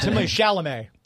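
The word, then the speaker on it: Timothée Chalamet: Timothy Chalamet.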